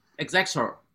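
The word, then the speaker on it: Effexor: If you are doctors or have a prescription pad, Poppy's medication is $500 a month for his, what is it called, X-Court? Pills. – Exexor. – You heard that one right there, ladies. That's exactly how it's pronounced.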